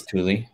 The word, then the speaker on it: Thule: Can you say Thule or Thule or what is it? I don't know. – I think it's Thule.